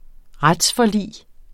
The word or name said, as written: retsforlig